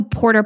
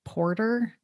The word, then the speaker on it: porta: The campsites that he would take me to, they didn't even have a porta potty. – Porter? It's a porta. – Porter? Is that how you say it? – Porta. – Porta.